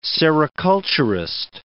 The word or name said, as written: sericulturist